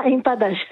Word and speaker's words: empadas: Et puis alors, on va terminer avec les empadas, c'est comme ça qu'on prononce ? – Ah, empadas, oui.